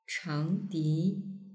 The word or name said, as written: changdi